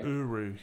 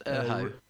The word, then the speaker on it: Urho: Uru, yeah. – Oh. – Uru. – I thought it was pronounced Urho. – Urho? – Urho. – Okay. Well, my Mac said it was Urho, but Uho also.